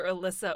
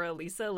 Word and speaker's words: Elissa: It's one of those things where it's like, I am not especially attached to people calling me either Alyssa or Alisa.